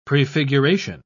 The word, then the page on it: prefiguration: British English